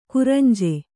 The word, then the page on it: ಕುರಂಜಿ: Kannada